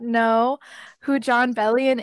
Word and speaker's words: Bellion: I was momentarily taken aback by the mispronunciation of his last name. But... – Sorry, John Bellion. Yes, it's John Bellion.